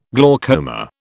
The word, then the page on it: glaucoma: British English